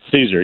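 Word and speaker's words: Szczur: Is it Caesar? – That's Caesar, yeah. Caesar. All hail Caesar.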